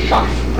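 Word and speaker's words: scharf: In German, schaff, pronounced schaff.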